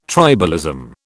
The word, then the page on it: tribalism: British English